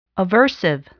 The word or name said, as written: aversive